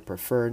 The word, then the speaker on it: prefer: We will consider the idea prefer. Now, this word is brought up in class because of the double R, so we pronounce it pre, like free, and then fur, like a fur on a cat.